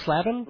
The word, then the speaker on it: Slavin: Is, is pronounced Slavin? Slavin. I've been slaving away mispronouncing it all this time.